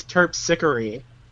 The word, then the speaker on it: Terpsichore: Apparently, the way you pronounce her name is Terpsichore.